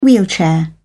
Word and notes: wheelchair: British English

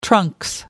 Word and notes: trunks: British English

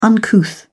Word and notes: uncouth: British English

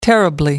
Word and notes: terribly: American English

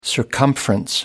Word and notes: circumference: American English

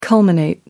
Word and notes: culminate: American English

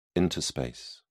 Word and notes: interspace: British English

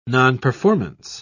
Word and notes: nonperformance: British English

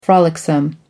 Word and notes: frolicsome: American English

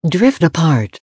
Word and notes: drift apart: American English

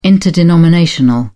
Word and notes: interdenominational: British English

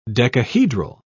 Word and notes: decahedral: British English

